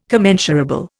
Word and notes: commensurable: Indian

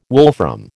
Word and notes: wolfram: American English

